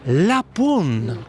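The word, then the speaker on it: la punt: La pône. Comment vous écrivez ça? – La ponte. On écrit, mais on dit seulement la pône. – Ok, on ne prononce pas le T.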